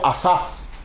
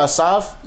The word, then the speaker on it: asaf: La palabra piadosos se pronuncia en hebreo asaf.